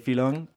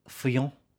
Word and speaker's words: Fillon: Og så hørte hun, at vi sagde filon, og så var hun bare sådan... – Ja, hvordan udtaler man? Fillon.